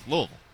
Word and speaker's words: Louisville: Yeah, that's the way they say it there. – Louisville. Lovely day to... – Are you doing that because you're a UK guy? – No, it's just how you say it.